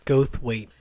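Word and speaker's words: Goldthwait: I don't know how to pronounce his last Gothwaite. – Name. What was it? – Gothwaite.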